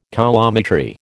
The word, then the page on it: coulometry: American English